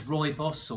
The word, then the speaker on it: rooibos: So this is rooibos or red bush or bush tea or red bush tea. People pronounce rooibos lots of different ways, but that's how I say it.